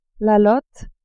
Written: lotte

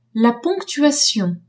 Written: ponctuation

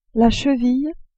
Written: cheville

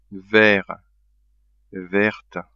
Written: vert-verte

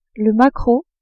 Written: maquereau

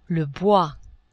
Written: bois

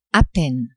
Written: a peine

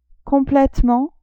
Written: completement

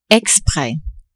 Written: expres

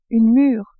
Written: mure

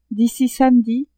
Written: d'ici (samedi)

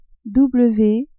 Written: w

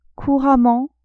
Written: couramment

